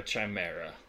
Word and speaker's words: chimera: Make her a chimera. It's pronounced chimera, but go on.